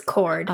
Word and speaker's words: Chord: It's Chord. – Oh. – Like Chord. Guitar Chord.